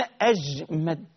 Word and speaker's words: أجمد: عندنا أحمد كتير، ها كتير أحمد، لكن عندنا أجمد، في راوي اسمه أجمد، أبوه سمى كده هنعمل إيه بقى؟ اسمه أجمد، الرسم هو الرسم لكن النطق يختلف.